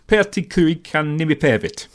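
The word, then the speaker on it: Pertti Kurikan Nimipäivät: It's Pertti Kurikan-Nimipervit, which means Pertti Kurikan's name day, which is the lead guitarist's name, Pertti Kurikan.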